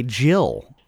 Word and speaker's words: gill: This is another case where the G has a J sound. It is G-I-L-L, a Jill. – Really? – Spelled G-I-L-L.